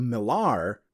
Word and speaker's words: Millar: According to Twitter, Mark Miller's Twitter, he said both pronunciations are correct, as Miller used to be a trade name, thus would be pronounced differently in different areas.